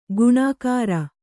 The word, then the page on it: ಗುಣಕಾರ: guṇakāra